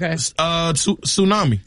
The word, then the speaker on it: tsunami: This has a silent letter in it. Tsunami.